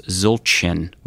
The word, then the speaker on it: Zildjian: Which their name would have been changed now to Zilchan, which is Z-I-L-C-A-N. Some people say Zilcan, but that's not how it's pronounced. The C would have a little tail on the bottom of it. – Okay. – So it's pronounced Zilchan, which means big bell.